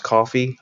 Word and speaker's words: Ko-fi: Now there's another angle that to that I'd be more than happy to hear about, and that's how you're doing with your is it pronounced coffee, I guess, KOFI, right? – Yeah, KOFI, and thank you.